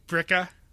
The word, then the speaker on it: Billerica: Bricka is really Bricka. People just drop the L's.